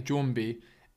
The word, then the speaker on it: Jwmbi: That's Jumbi. Jessica's yet to record her part for this, and I haven't heard how she's going to say it, but I'm saying Jumbi. It's spelt J-W-M-B-I, so you pronounce that.